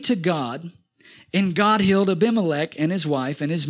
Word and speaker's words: Abimelech: And Abimelech, that's how you say it.